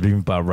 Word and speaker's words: bibimbap: And no white person ever pronounced bibimbap right. It's like bibimbap.